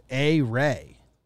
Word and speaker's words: Ehire: Okay, according to Baseball Reference, it's A-Ray. A Ray Adrianza.